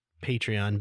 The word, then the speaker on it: Patreon: But I'm pretty sure it's Patreon because it's like a patron.